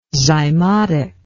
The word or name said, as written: zymotic